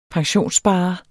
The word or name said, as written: pensionssparer